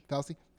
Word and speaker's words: Fauci: How do you say Fauci? – Fauci.